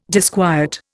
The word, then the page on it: disquiet: Indian